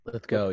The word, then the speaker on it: Lithgow: Lithgow, yeah.